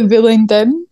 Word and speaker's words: Bullingdon: At the Bullingdon. Is that how you say it? – Yeah.